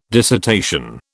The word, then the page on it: dissertation: British English